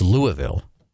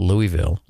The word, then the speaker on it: Louisville: It's Louisville. It's not Louisville. It's not Louisville.